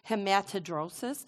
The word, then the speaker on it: hematidrosis: Hematidrosis. Did I say that right? Thank you.